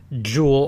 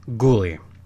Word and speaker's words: Guhle: Yes, that is how you pronounce his name. It is spelled G-U-H-L-E. It is not Jewel or Ghoul. No, it is Gulli.